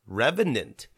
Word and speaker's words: Revenant: Next is the Revenant.